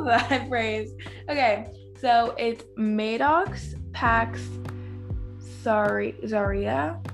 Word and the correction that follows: Zahara: I'm sorry, I'm butchering that. – I think it's Zahara. – Zahara, yeah, that's it.